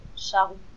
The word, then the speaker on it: Sharouh: actually, it's just my friends in France used to call me like this, but it's French. In French, it sounds different. It's pronounced Charou, and yeah, my friends and family sometimes call me like this, and.